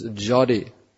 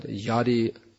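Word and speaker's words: jadi (যদি): So accordingly, you have to pronounce ja or ya. So this jada, what is that verse? Jada deke tarikoi Krishna upadesh. That's jadi, not yadi, yadi.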